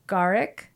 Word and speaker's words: Garik: And it's, okay, I'm going to pronounce it right. Garik. – Yes. – And Britney. – Yes. – Correct. Thank you for saying it right.